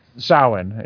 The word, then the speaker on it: Samhain: They even used the word Samhain, pronounced Samhain. – Samhain. – Yeah, she spells it S-O-W, but that can go so or sow. So Samhain. And basically she spells it correctly,